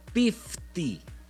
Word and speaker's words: piftie: En Rumania, el guisado tradicional para la Navidad es algo que se llama pifti, con P de Pedro. – ¡Órale! – Se escribe piftie.